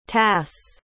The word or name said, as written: tasse